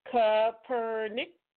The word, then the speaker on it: Kaepernick: Kaepernick.